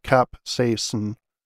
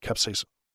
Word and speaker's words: capsaicin: Capsaicin, which is, yeah, it's pronounced capsaicin, is psychoactive. So it is a psychoactive substance, which I'm not fully clear on exactly what that means, but I know it makes me feel good. And I think it might be slightly addictive, but I love spicy food. So yeah, that's how you pronounce that, capsaicin.